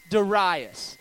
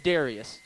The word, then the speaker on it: Darius: And I learned this week through YouVersion Bible app that I've been saying this name wrong. I've always said Darius. You know what I mean? Does it look like Darius to you? But as I was going through YouVersion Bible app, it says Darius.